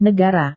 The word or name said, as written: negara